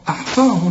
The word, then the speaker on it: أحصاه: وعندك بعد الصاد الألف عشان تمد ألف كاملة مكتوبة بدون ألف، لكن تنطق بالألف وتكتب هكذا بدون ألف، أحصاء، أحصاه الله. طبعا ما دام الهاء مضمومة يبقى لفظ الجلالة هنا مفخم، أحصاه الله.